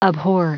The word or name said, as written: abhor